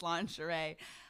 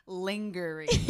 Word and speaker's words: lingerie: And she goes, a girl just pronounced lingerie lingering.